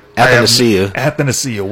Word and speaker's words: Athanasiou: Athanasiou, whatever.